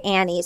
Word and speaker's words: Anne: But Stacey, our producer, did say she's heard other interviewers call her Anne because it's spelled A-N-N-E. And Annie said at the end, she goes, I go by Annie. So everyone thinks it's spelled A-N-N-I-E. So it's Annie. We say Anne.